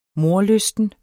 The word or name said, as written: mordlysten